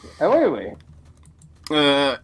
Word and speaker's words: Ai Weiwei: I think it's Ai Weiwei? Ai Weiwei? – Weiwei. Ai Weiwei.